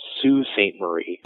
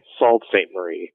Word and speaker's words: Sault Ste. Marie: Because it's actually pronounced Sault Ste. Marie. – Ah, okay. – Sault Ste. Marie.